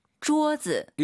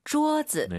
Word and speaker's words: 桌子: 桌子. – 일성경성, 엄마를 생각하시면서, 마마. 이 발음과 똑같죠? – 桌子. – 네, 이 억양을 기억하세요.